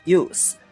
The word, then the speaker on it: youth: 还比如说年轻的名词叫做 youth，youth，y o u t h，这里面大家注意啊，不要读成 youth，这样的话你就变成了使用那个单词后面这个 t h 它是一个咬舌的音。